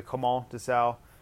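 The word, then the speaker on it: Clément Desalle: Clément Dessau, if you want to say it correctly, Clément Dessau,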